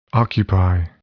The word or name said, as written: occupy